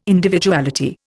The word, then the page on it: Individuality: Indian